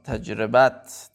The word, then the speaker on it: تجربت: تجربت تجربت تلفظ درستش